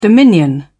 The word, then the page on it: dominion: British English